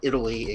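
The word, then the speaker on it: Eataly: It's called Pronto Italy, and I'm not mispronouncing it. It's just a pun. It's like Italy, except it's E-A-T-A-L-Y.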